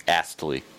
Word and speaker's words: Astley: Astley.